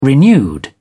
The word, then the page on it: renewed: British English